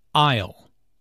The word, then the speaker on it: aisle: I'll. Notice the silent S in aisle.